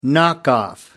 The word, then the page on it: knock off: American English